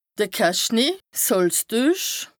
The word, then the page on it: de Cache-nez / 's Hàlstuech: Bas-Rhin; Strasbourg